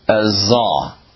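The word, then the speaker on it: Azah (עזה): Gaza, if you pronounced it in Hebrew, is Azah.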